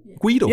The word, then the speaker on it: Guido: Jeg ved ikke, hvordan du udtaler det. G-U-I-D-O. Guido.